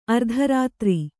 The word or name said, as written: ಆರ್ತರೌದ್ರ